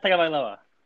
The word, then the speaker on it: Tagovailoa: Taulia Tagovailoa.